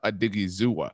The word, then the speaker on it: Odighizuwa: Odighizuwa, a little bit of a more athletic defensive tackle overall. – Nice job on the pronunciation, by the way.